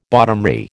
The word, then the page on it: bottomry: American English